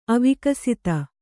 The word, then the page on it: ಅವಿಕಸಿತ: avikasita